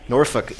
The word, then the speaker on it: Norfolk: Norfolk.